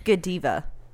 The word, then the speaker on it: Godiva: Pronounced Godiva.